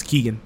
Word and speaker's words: Keoghan: I don't but it's spelled Keoghan. But I think you're right. I think it's Keegan.